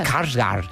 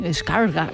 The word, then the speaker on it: Skarsgård: Y qué bien lo pronuncias. – Skarsgård. – Sí, Skarsgård. – Es como pronunciarlo como Dumbledore de Harry Potter. Señor Skarsgård. Un poco así.